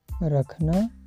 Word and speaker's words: rakhna: The pronunciation of this verb rakhna, r-a-k-h-n-a, rakhna.